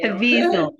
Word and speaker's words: Caviezel: But um, I feel um, grateful that they went out of their way, Mel Gibson and James Cavilla. – Cavilla, mm. Mm-hmm. – Cav- how do I pronounce his name? – I can't say it either, to be honest with you. Caviezel. Jim Caviezel. Caviezel.